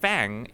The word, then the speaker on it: FAANG: So, Fang is actually FAANG. That's how they spell it.